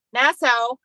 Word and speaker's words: Nassau: Nassau.